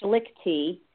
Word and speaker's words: Schlichte: And I have brought on owner of Elite Bronzing, Mary Beth, and wait for it, it's Schlichty.